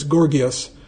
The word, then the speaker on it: Gorgias: I teach Plato's Gorgias. I hope I'm pronouncing that right. I think that second G is a hard G. Plato's Gorgias.